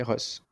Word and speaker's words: ROS: Donc, il y a cette boîte à outils ROS. Je le prononce bien ? – Oui, Ross.